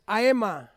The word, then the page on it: aema: Ansus